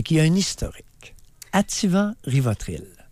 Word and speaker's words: Ativan: Ativant les copains, il y a 10 ans, il y a une décennie et plus, Activant, comme prononcent beaucoup de personnes. Ils mettent un C. J'ai jamais compris le activant. C'est activant. Il n'y a pas de C après l'A.